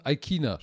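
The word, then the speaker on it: Ikhine: Sie haben Tannehill, sie haben Derek Handy, sie haben Robert Woods, AJ Brown, Westbrook Aikini oder Aikina, Aikina spricht man den aus.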